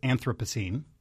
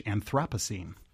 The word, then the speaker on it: Anthropocene: It's Anthropocene, or if you're British, Anthropocene. So those are the three contenders.